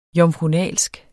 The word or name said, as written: jomfrunalsk